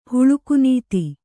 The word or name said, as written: ಹುಳುಕುನೀತಿ